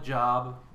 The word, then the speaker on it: Job: It's spelled like Job. It's pronounced Job.